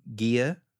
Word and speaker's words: Gia: He's married to a lady named Gia. That's how it's pronounced.